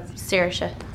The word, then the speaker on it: Saoirse: I kind of pronounce it somewhere between Saoirse and Saoirse, so Saoirse.